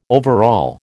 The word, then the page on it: overall: American English